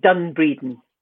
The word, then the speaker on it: Dunbreedin: Yeah, D-U-N-B-R-E-E-D-I-N, Done Breeding.